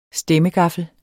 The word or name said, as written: stemmegaffel